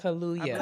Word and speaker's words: Kaluuya: Kaluuya. Kaluuya. Kaluuya. Hot like Kaluuya. hallelujah. Okay, Kaluuya.